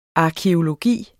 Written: arkæologi

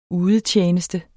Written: udetjeneste

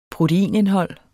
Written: proteinindhold

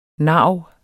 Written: narv

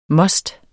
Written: must

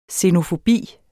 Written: xenofobi

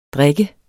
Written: drikke

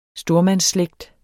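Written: STORMANDSSLÆGT

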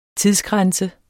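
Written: Tidsgrænse

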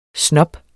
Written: SNOBBERNES

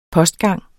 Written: postgang

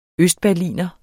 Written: berliner

